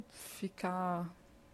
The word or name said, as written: ficar